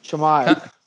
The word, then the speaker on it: Chimaev: What's his, how do you pronounce his name? Anyway. – Chimaev. – Chimaev.